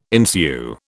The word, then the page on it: ensue: British English